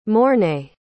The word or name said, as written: mornay